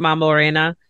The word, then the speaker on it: Mambo Reina: Am I pronouncing that right? Mambo Reina? – Yes. – Okay, I just like, because it's spelled like, you know, queen in Spanish, so that's kind of what I'm going off of.